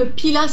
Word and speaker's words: Pilat: Donc en fait, euh, il faut savoir que Pila s'écrit P-I-L-A-T, puisque c'est un mot euh, qui arrive du gascon et qui signifie ta ».